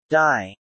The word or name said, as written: die